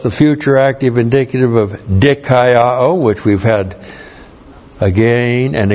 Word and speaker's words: dikaiō: D-I-K-A-I-O, long O, dikaiō.